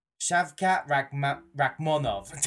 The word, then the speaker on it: Shavkat Rakhmonov: I said that right. Yes, I said it right. Shavkat Rakhmonov.